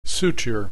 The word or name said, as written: suture